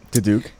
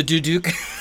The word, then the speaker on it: duduk: How do you say, duduk? – The duduk.